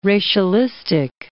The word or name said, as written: racialistic